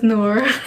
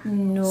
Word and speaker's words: Noor: It's pretty much as you spell it. So it's N-O-O-R. – Noor.